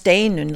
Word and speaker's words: Steinunn: My name is STEiNUNN. My full name is STEiNUNN Viðar Sigurðardóttir, which sounds awfully long. But you pronounce STEiNUNN like the word stay, nun.